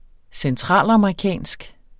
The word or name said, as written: centralamerikansk